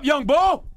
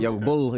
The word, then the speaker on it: young bull: Did I say that right, Eddie? – No. – Young? – I didn't say that right. Young Bull is Young Bull. – They want you to act up, Young Bull! I didn't say that right? – That's better. – Okay. – A little better.